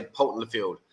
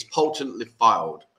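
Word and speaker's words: Poulton-le-Fylde: It was from someone else, because I just say Poulton Lefield. And they said, that's not how you pronounce it. So, okay, it's Poulton Lefield.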